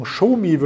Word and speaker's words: Xiaomi: Äh, Xiaomi, wir hatten am Freitag kurz darüber gesprochen, Xiaomi wird das Ganze ja ausgesprochen und bei euch gibt es ja auch den Flagship Store um die Ecke.